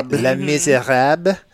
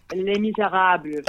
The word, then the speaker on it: Les Misérables: Let me help you help yourselves because that was gruesome. It's Les Miserables.